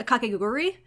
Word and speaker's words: Kakegurui: Is that just Kagekuri? I should ask Kawaii Disappointment. They've watched that show. – Yeah, Kakegurui? – I've been pronouncing it wrong every time.